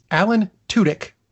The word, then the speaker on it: Tudyk: I heard a definitive version. It's Alan Tudyk.